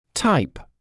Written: type

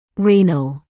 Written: renal

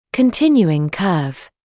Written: continuing curve

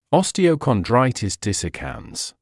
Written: osteochondritis dissecans

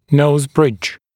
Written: nose bridge

